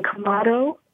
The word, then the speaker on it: Quemado: Box 87 in Kamado, and that's spelled Q-U-E-M-A-D-O, Texas, 78877.